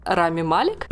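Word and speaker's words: Rami Malek: играет его Рами Малек. What? если я правильно ставлю ударение. – Не совсем. – Нет, э, Рами Малик.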